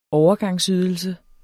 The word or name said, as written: overgangsydelse